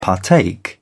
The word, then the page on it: partake: British English